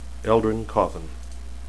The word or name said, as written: eldrin